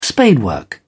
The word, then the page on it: spadework: British English